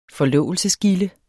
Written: FORLOVELSE

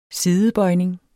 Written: sidebøjning

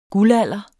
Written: guldalder

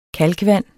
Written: kalkvand

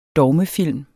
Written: DOGMEFILM

